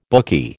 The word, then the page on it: bookie: American English